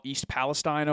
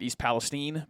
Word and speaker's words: Palestine: A train from Norfolk Southern, I think they're called, derails in a town that I'm going to call East Palestine, Ohio, despite the entire media pronouncing it East Palestine, because that's what the people from there say. It's spelled Palestine, so I'm going to say it.